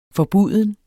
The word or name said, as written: FORBUDENT